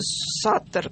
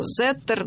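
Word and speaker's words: Sutter: Значит, купил Форт Росс человек по фамилии, кто произносит Саттер, кто Зеттер.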